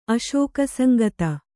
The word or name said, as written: ಅಶೋಕಸಂಗತ